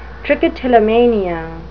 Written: trichotillomania

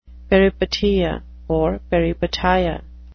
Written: peripeteia

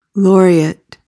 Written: laureate

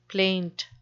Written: plaint

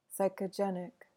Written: psychogenic

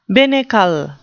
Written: binnacle

